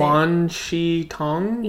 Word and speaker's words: Wan Shi Tong: How do you say Wan Shi Tong? – Yeah, the owl, the library.